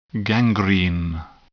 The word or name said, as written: gangrene